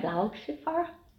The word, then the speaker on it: Blauschiefer: Blau, B-L-A-U, é azul em alemão e Schiffer, a gente já sabe, é ardósia. Olha a pronúncia correta agora. – So this now is um, the 2021 Blau Schiffer. So blue slate, Riesling dry again. Blauschiefer. Perfect.